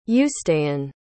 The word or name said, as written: uistean